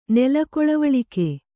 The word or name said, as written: ನೆಲಕೊಳವಳಿಕೆ